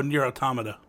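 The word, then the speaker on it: NieR:Automata: Is that how you pronounce that? – Oh, Nier Automata. – Oh, that's the one. Yeah. I'm thinking of a tomato.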